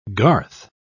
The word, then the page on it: garth: British English